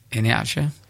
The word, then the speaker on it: Iheanacho: How do you pronounce Kelechi's name? – Iheanacho. – There we go.